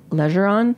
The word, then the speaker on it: Legeron: And look out for Jordan's interview with Isabel Legeron. Am I saying that right? – Yeah.